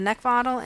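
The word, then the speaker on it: Neckvatal: You can also follow me on Twitter, LinkedIn, and YouTube under Brenda Neckvottel. And my last name is spelled N as in Nancy, E-C-K, V as in Victor, A-T-A-L.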